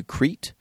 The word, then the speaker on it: Crete: Did I say Crete? – Yeah. – Crete. – Yeah. – Like crepe. – Kind of. – Crepe. – Only with a T.